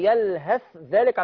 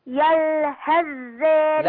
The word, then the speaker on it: يلهث ذلك: لا اوعي تنطق الثاء، مش هتضغم في الذال. يلهث، اظهري لي الثاء لوحدها. يلهث ذلك. – يلهث ذلك. – لا انا سامع صوت ذال.